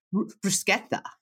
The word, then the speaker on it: bruschetta: Bruschetta.